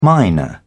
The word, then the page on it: minor: British English